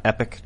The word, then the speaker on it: Epoch: Hey, is it confirmed that it's called Epoch Times and not Epic Times? – Both pronunciations are correct. I say Epoch because I'm from Appalachia. So that's, you know, just naturally where my accent goes.